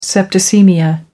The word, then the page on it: septicaemia: American English